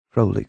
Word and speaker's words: Crowley: Magic, the great work, Crowley calls it. – She pronounced magic with a long A and Crowley with a long O, like the bird.